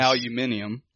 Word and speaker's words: aluminium: And super smart, always, for whatever reason, pronounced aluminum in the English fashion, yes. – Aluminium.